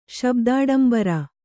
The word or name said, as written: ಶಬ್ದಾಡಂಬರ